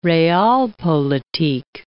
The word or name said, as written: realpolitik